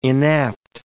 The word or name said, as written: inapt